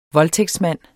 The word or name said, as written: voldtægtsmand